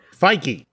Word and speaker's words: Feige: Feige? – There we go. We'll the Avengers guy. I'm butchering his name, and I'm so sorry. – Feige.